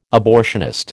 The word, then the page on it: abortionist: American English